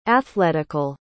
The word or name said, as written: athletical